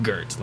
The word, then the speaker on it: Goeth: You can spell it G-O-T-H, like we spell goth, but, or G-O-E-T-H, Gert. But that's how they say it the entire time. It's like Gert. Like there's an R in it or something.